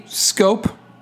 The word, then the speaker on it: Skopje: Capital city, Skopje, S-K-O-P, silent J-E, I believe.